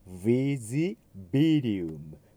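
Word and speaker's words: invisibilium: それからヴィジという方は V の音ですので、下唇と前歯を使ってウッっていう発音になる。その次のものは B ですので、上下の唇が破裂したビって音になる。ヴィジビリウム、この区別はやりにくい言葉ですから、ちょっと注意して発音してください。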